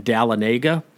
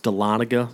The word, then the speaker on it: Dahlonega: Back in Atlanta, you could always tell the new weather guy on the news because he would say it's raining in Dahlonega. It's actually pronounced Dahlonega. It's a little mountain town in North Georgia. But we always got a kick when the new guy pronounced it Dahlonega.